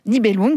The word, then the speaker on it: Nibelung: Alors, comment on prononce ? – Nibelung, oui.